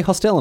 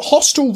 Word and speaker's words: hostel: No, we say hostel in Australia. – That's bad. – So hostel rhymes with Adele.